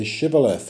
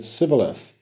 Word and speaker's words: Shibboleth: And if he said no, then they said to him, "Now say Shibboleth." And he said Sibboleth, because he couldn't manage to pronounce it right.